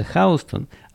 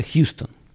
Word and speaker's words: Houston: Хаустон, вернее, оно пишется Хаустон, а читается Хьюстон.